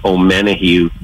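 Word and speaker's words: Omenihu: Charles, I'm going to mispronounce his last name, O'Menahue.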